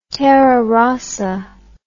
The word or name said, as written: terra rossa